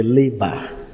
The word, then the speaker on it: laver: Say the laver. It is spelled L-A-V-E-R, the laver.